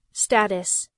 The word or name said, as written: status